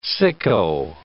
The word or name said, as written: sicko